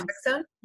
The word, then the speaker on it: naltrexone: Is it naltrexone? How do you pronounce naltrexone? Yep.